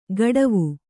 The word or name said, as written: ಕಡಾವು